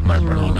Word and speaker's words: Marlboro: Oye, sí, es que una R nunca suena. No se pronuncia. – ¿No se pronuncia? – Marlboro. Nadie dice Marlboro.